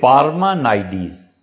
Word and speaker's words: پارمانائیڈیز: 500 سال پہلے یونانیوں کی اس تہذیب میں افلاطون، اور افلاطون کا ایک معاصر تھا، مختلف طرح پہ اسے پڑھا گیا ہے کہ اس کا نام کیا تھا، اور ایک تلفظ اس میں سے یہ ہے کہ پارمانائیڈیز.